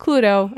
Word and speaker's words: Cluedo: So in the UK, for all you UK people, your board game Clue is called Cluedo. Cluedo? It's like the word clue with do at the end, but it's pronounced Cluedo, and I don't know what that word means.